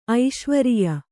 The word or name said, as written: ಐಶ್ವರಿಯ